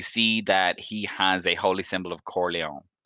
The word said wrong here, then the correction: Corellon: Sorry, not Corleone. I always say this wrong. Corellon. A holy symbol of Corellon.